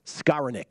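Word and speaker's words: Skowronek: Matt Grissom says, Skaronik. That's how you pronounce the Rams wide receiver's last name.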